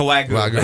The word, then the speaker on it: Wagyu: The Wagyu. – Oh, my God. Yes, that Wagyu steak is amazing. – Yeah. – It was my birthday last night, so he treated me to a Wagyu steak.